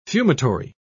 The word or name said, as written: fumitory